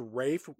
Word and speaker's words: Ralph: When the movie starts off, we see John Steed, our primary the Avenger, as played by Ralph Fiennes, but why is his first name pronounced Rafe when it's spelled Ralph? – Well, because he's British and fancy.